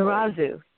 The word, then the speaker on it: Narazu: It's pronounced, is it Narazu? Did I pronounce that correctly? – Narazu, yes.